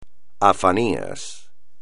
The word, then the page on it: Afanías: espagnol